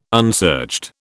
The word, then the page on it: unsearched: British English